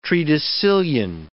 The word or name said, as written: tredecillion